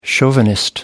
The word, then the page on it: chauvinist: American English